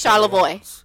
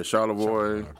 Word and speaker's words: Charlevoix: Oh, it's Charlevoix. – Yeah, Charlevoix.